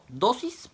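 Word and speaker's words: dosis (δόσις): La palabra dádiva que sería la primera, viene del griego dosis, con acento en la O, dosis, que significa regalo.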